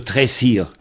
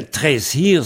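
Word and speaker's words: traezh: Alors, on a ce mot-là dans le nom d'une grève à l'ouest de Brest qui s'appelle « le Tressir ». Les Brestois connaissent bien ça, « le Tressir ». Eh bien, « Tressir », ça veut dire « grève longue »,« plage »,« grève »,« sable ».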